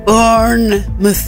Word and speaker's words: Bournemouth: Bournemouth. – Mycket riktigt, perfekt uttal.